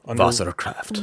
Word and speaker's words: Wasserkraft: So the W's sound like V's, right? – Yep. – Okay. This is called Wasserkraft.